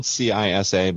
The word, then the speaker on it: CISA: It's spelled CISA, but pronounced CISA.